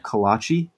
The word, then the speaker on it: kolache: Kolache. Yeah. – Okay. I Googled a picture. I meant to Google like an actual, like a Shipley kolache to see what the hell it actually looked like. And kolache.